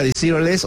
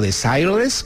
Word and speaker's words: Desireless: Aquí estaba Desireless o Desireless, depende de si lo pronuncian en francés o en inglés.